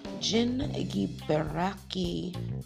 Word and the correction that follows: Zingiberaceae: Did I say that right? Zingiberaceae. I think it's Zingiberaceae.